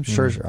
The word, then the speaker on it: Scherzer: Scherzer, all right.